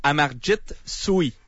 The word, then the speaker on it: Amarjit Sohi: Amarjit Sohi. – C'est bien. Okay. – C'est bon. Je pense même prononciation des deux fois, fait que c'est correct.